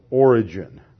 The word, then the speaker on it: Origen: And then the guy he really influences is a guy by the name of Origen. That's spelled O-R-I-G-E-N.